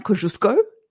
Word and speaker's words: Kosciuszko: Well, In a couple of weeks, I am going down to Mount Kosciuszko, as I think the proper pronunciation is.